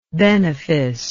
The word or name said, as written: beneficeless